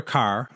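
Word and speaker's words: Kerr: Today our topic is the classic 1947 British film, Black Narcissus, written and directed by Michael Powell and Emmerich Pressburger, and starring Deborah Carr, my wife tells me that's how it's pronounced, Kathleen Byron, and David Farrar.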